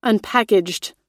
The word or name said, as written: unpackaged